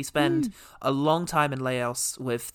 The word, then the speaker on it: Laos: I, in my head, pronounce it Laos. – Laos. – Laos. – That sounds good.